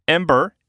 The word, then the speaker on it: Embr: Ember is a game that was announced a while ago. It's spelled E-M-B-R,